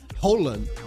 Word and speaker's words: Haaland: que eu descobri que a pronúncia correta é Haaland. Haaland.